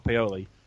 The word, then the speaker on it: Pioli: But you've been pronouncing his name wrong, because he's Scott Pioli. It's an I, not an O.